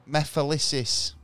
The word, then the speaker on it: Mephistopheles: hang on, let me say this right. Mephilisis. – Okay. – Yeah. Which is spelled Mephistopheles.